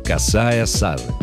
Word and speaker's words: косая сажень: Сажень указывает, что знаменитый фразеологизм – это сложившийся устойчивый оборот, и именно его нужно произносить с ударением на первом слоге. Косая сажень.